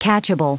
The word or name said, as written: catchable